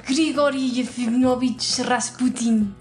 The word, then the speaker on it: Grigory Yefimovich Rasputin: Su nombre de pila era Grigory Yefimovich Rasputin.